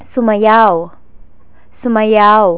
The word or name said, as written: sumayaw